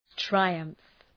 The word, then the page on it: triumph: traı’ʌmf